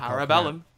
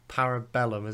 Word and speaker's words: Parabellum: Parabellum. – Parabellum, is that right? – Mm-hmm.